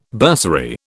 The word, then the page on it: bursary: British English